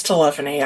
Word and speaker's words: telephony: Telephony. Yeah, I said telephony. It's telephony. I'm sorry. So. – You just put the wrong syllable in the wrong place or something.